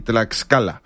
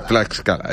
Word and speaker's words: Tlaxcala: No sé cómo se pronuncia esto realmente, Chacala, Tlaxcala, Tlaxcala, México, lo estoy buscando, pero me lo sé de memoria. Mira aquí, Tlaxcala. – Tlaxcala.